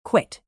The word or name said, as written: quit